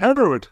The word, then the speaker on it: Hereward: I will do my best, but Hereward, is that right? – Yeah, Hereward, absolutely, yeah.